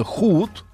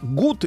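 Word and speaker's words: Hood: В 1247-м скончался сегодня благородный рабо- э, разбойник э, Робин Худ. У нас он ошибочно читается как гуд и думают, что он э, гуд это хор- хороший. На самом деле худ.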